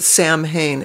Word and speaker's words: Samhain: Um, Halloween, of course, originally was a Celtic the celebration of the autumn festival called Samhain. Some people pronounce it Samhain, S A M H E I N.